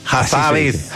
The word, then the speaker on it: Hassabis: Demis Hassabis. ¿Se dice así? Hassabis. – Hassabis. Es un personaje. – Habibi, así como Habibi.